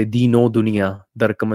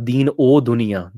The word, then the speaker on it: Deen O Dunia: But actually the way it's pronounced is Deen O is actually Deen dash O, like as an Ure Nuhura, Deen O Dunia.